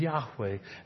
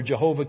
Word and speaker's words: Yahweh: His name should come easily to your lips, and his Father's name is Yahweh, and that should come easily to your lips as well. Now you know where Jehovah came from, and while you understand it's a Germanic mispronunciation, it's not a big hairy deal.